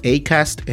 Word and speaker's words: Acast: Se pronuncia Acast en español, pero es Acast en inglés.